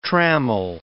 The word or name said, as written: trammel